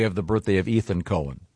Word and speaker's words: Cohen: This that's confusing because not when it's spelled C-O-H-E-N. – It's definitely Cohen.